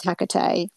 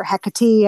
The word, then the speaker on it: Hecate: I believe I probably wasn't ready to work with Hecate, or Hecate, I've heard pronounced both ways.